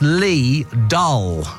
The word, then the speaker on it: Lidl: Lidl is pronounced Lee Dull, with the first syllable like the English word Lee and the second sounding like dull. It's a German name, and the I in German is pronounced as a long E sound.